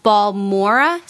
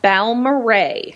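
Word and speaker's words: Balmorhea: Am I saying that right? – No, it's called Balmoray. – Oh, Balmoray. Okay, didn't get it right. – A little bit more musical. A little bit more Texan.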